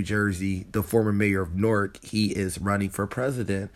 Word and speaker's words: Newark: It's pronounced Newark by people from Newark. It's spelled like Newark. We got one in Delaware, where the University of Delaware is, but in Jersey, they call it Newark.